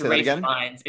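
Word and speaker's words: Ralph Fiennes: Well, I didn't know they pronounced Ralph like Rafe.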